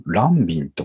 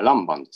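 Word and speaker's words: Lanvin: ランビンと書いて何て読むんですかね、あれ。 – あ、ランバンですね。 – ランバンですか。 – はいはい。 – ちょっとおじさんブランドですかね、ランバンって。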